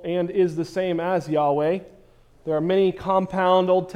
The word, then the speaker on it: Yahweh: Again, as I said several weeks ago, I believe a closer pronunciation is Yahweh.